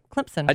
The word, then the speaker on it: Clemson: Clemson.